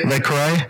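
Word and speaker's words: Le Carré: I compare him, though, to people like John Le Carre. Is that how you pronounce his name? – Yeah. – Le Carre? All right.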